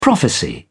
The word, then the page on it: prophecy: British English